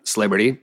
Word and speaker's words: East Liberty: I went for a run from downtown to Sliberty, which is how Pittsburgh people pronounce East Liberty.